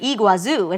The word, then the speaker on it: Iguazu: So her handle's Angel, but her name's Nora, and it's Iguazu, Argentina. – Argentina. – Yes. Glory to God for your lives. I hope I'm saying that right. Iguazu.